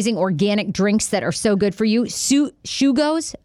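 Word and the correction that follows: Sugo's: Am I saying it right? – Sugos was awesome in Addie's restaurant.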